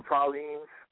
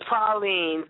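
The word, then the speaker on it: praline: Like those, what do you call them, pralines? – The pralines.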